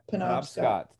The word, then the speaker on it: Penobscot: Am I pronouncing that correctly? Penobscot? – Penobscot.